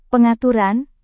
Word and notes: pengaturan: Indonesian

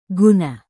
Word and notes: guna: Indonesian

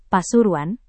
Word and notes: pasuruan: Indonesian

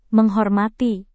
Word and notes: menghormati: Indonesian

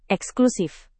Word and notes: eksklusif: indonesian

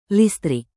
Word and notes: listrik: indonesian

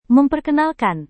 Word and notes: memperkenalkan: Indonesian

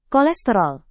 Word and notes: kolesterol: indonesian

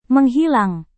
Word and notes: menghilang: Indonesian

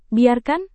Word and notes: biarkan: Indonesian

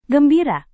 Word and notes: gembira: Indonesian

